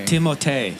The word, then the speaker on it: Timothée: Timothée Chalamet. – It's Timothée.